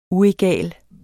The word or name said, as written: UEGALT